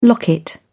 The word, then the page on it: locket: British English